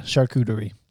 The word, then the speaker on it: charcuterie: Charcuterie. Did I say that right? – You did, yeah. Charcuterie.